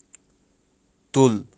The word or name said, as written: tull